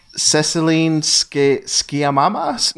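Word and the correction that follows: Céline Sciamma: No, that's not right at all. – Well, director, I know her. That's Celine Sciamma.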